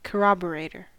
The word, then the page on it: corroborator: American English